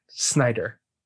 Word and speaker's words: Snyder: the Rob Schneider cut. – Oh, did I say it wrong? – You said Schneider. – Instead of? – Snyder.